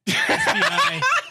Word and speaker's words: Spiaggi: SPIAGGI. – All right, yeah. How would you say that? I'd say Larry Spiaggi. – Spiaggi? – Yeah, okay. It's like I want to see. – It's Italian Miyagi.